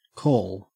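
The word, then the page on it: call: au Australian English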